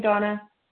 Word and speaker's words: Dana: I do spell my name D-A-N-A. Although pronounced Donna.